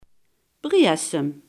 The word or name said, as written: brasem